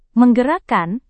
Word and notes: menggerakkan: Indonesian

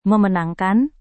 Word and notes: memenangkan: Indonesian

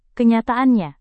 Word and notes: kenyataannya: indonesian

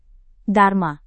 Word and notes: dharma: Indonesian

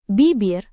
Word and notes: bibir: indonesian

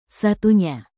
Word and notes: satunya: indonesian

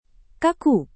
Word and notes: kaku: Indonesian

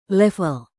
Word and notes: level: indonesian

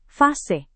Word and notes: fase: indonesian

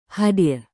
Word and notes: hadir: Indonesian

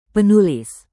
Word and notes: penulis: indonesian

